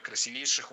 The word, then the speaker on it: красивейших: Красивейших. Неверно. Правильно, красивейших. И здесь опять же проблема с ударением. Напоминаю, мы сохраняем ударение во всех формах. Оно должно стоять там же, что и в исходном прилагательном.